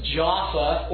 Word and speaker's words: Jaffa: They, in your Bible, it's spelled Joppa. It's pronounced Jaffa or Jaffa in their language.